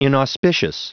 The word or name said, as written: inauspicious